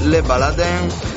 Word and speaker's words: Le Baladin: Hai letto Le Baladin perché si pronuncia così o perché sei il piemontese? Tu potremmo... – Ma l'ho letto alla francese, diciamo.